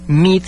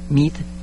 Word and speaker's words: Meade: Celestron, MID, se escribe M-E-A-D-E, MID, muy buena marca.